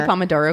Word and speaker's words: Pomodoro: I always say Pomodoro because those are tomatoes. – It is. That's what it is. You're saying it right. I'm saying it wrong. Yeah, because it's a tomato timer.